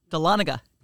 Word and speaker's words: Dahlonega: Dahlonega. Okay, yes. Sorry to get it wrong again. I did not learn my lesson the first time. But he lays it down on Dahlonega.